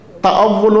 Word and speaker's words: تأول: دوسرا شعبہ یقین کا تأول الحکمہ، تے الف واو لام.